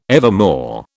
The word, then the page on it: evermore: British English